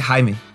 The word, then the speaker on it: Jaime: eu, sempre falei Jamie também, só que o, a, a, pronúncia da família me conquistou muito. Então agora pra mim é Jaime.